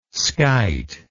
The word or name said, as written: skite